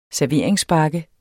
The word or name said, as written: serveringsbakke